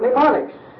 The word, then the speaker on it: mnemonics: That's spelled M-N-E. Not with an N to begin with, any more than pneumonia starts with an N. That starts with a P too, doesn't it? But mnemonics, it has reference to something that will aid the mind.